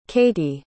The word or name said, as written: kattie